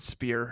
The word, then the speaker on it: Spear: The BSC secretary is Marianne Spear. Her last name rhymes with cheer, yeah. – not crier.